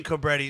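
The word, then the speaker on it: Cobretti: Yeah, because I was going to say, because in the Stallone movie Cobra, Marion Cabretti is the cop. – Well, maybe that, is that how you pronounce it? – Yeah.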